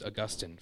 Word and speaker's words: Augustine: It's pronounced Augustine, folks, whether we're from Florida or not.